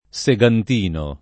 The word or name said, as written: segantino